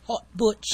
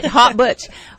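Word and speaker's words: Haute Butch: How do you call it? – Hot Butch. – I said it right. Hot Butch. Ooh, and hot is H-A-U-T-E.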